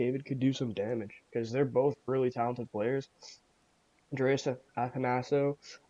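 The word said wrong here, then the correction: Athanasiou: How the hell do you know his name? – Athanasiu. – Athanasiu. Okay, there we go. I'll pronounce it correctly from now on.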